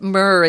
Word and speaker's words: myrrh: You'll never see that again, but myrrh is how that's pronounced.